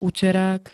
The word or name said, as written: uterák